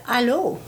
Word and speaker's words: allô: It is allô, justement. It's spelled A-L-L-O with accent circumflexe on the O.